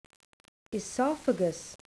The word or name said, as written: oesophagus